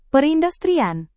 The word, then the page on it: perindustrian: Indonesian